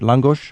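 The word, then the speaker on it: lángos: Peter, introduce us to langos. Am I pronouncing it right? Langos? – Perfect, yeah.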